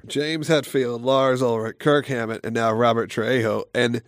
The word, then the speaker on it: Kirk Hammett: Is it Kirk or Kurt Hammett? – Kirk Hammett, with a K.